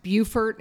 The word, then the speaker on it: Beaufort: Another thing is the town is pronounced Beaufort and it's spelled Beaufort.